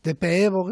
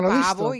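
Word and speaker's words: Pääbo: Las, últimas publicaciones del equipo de, Pevo, que es como se pronuncia. – Pevo, vale. Mejor que eh, yo decía Pavo y por lo visto, Pavo, sí. lleva unos diéresis ahí que al parecer.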